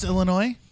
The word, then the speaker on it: Illinois: It's pronounced Illinois? – Yeah.